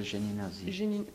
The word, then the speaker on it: Géninazi: Comment vous dites Michel Géninazi. – Comment ça s'écrit ça, Géninazi? – Comme ça se prononce. G-G-Je. – Oui. – Ni. – Ni. – N-I. – Oui. – Na. Na-Zi. Oui, c'était un peu un nom.